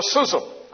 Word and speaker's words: schism: Satisfaction assumes a schism. That's the way you pronounce it.